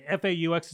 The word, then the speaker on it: faux: Okay, F A U X is pronounced faux. – Is it really? Yeah, it's French for false. Thank you. You're welcome. – Like faux pas.